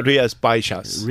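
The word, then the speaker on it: Rías Baixas: And you pronounce that Rias? Uh, Rias Baixas. Rias Baixas on the label as well.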